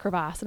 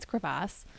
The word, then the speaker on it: crevasse: I think you need to pronounce it crevasse. And I was like, well, I thought that was spelled differently, but apparently it's not, it's crevasse.